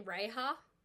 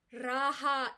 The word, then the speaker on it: Raha: But recently I was talking to someone about Alia Bhatt and Ranbir Kapoor's daughter, and they were like, oh, is her name Reha? Raha is her name, right? And it sounds better when you pronounce it the way it's intended to be pronounced.